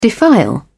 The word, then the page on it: defile: British English